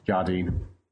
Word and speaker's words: Jardine: A lot of people tend to put more emphasis on the je, which makes me sound kind of exotic and French. – Oh, how do you say it? – Jardine.